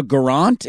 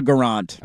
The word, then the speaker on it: Guerrant: Dakota Garant. – Okay, Dakota Garant. – I don't know if that's, don't quote me on the pronunciation, but I believe that's how you say it. But the spelling is G-U-E-R-R-A-N-T.